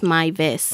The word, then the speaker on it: Meiwes: Armin, A-R-M-I-N. – Okay. – Then Mives, M-E-I-W-E-S, because he's German. – Oh. – So it's pronounced as Mives.